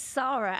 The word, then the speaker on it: Sarah: So my first question is, do you pronounce it Sarah or is that just the American way? Is it Sarah? – It's Sarah, actually, okay.